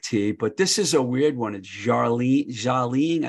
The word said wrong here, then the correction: Darjeeling: I can never say it right. – Jarlene. – You're not supposed to pronounce the D, I don't think. – Darjeeling.